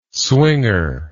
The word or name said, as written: swinger